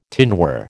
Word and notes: tinware: American English

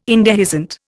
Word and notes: indehiscent: Indian

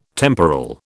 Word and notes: temporal: British English

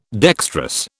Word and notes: dextrous: British English

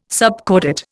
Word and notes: subcordate: Indian

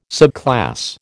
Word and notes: subclass: American English

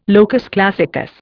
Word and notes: locus classicus: Indian

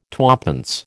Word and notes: twopence: American English

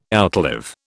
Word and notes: outlive: British English

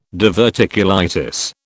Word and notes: diverticulitis: British English

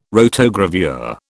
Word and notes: rotogravure: British English

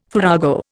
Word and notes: farrago: Indian

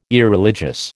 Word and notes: Irreligious: American English

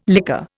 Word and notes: liquor: Indian

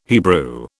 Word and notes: Hebrew: British English

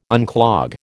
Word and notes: unclog: American English

